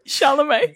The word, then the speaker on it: Chalamet: Was it the He looks like the Call Me By Your Name guy, Michelle Blay or whatever his name is. – Timothée Chalamet. – Chalamet. Yeah. I did fucking School of Rock on his surname. Sorry about that.